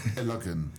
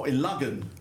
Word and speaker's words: Illogan: Or is it Illogan Highway? – See, this is the true pronunciation of someone not from Cornwall. Illogan. – Oh, Illogan. – Illogan Highway. – There's no U in there.